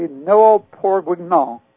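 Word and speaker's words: Noëls bourguignons: What was the correct pronunciation of that carol book of 1701? – It would be Noël pour Guignon.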